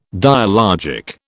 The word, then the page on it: dialogic: British English